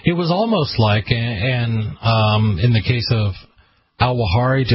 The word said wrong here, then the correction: al-Awlaki: Anwar al-Awlaki.